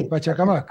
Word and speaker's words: Pachacamac: ¿Esto es cómo se pronuncia? ¿Pachacamac o Pachacamac? – Sí. Sí, Pachacamac, ¿no? Así es.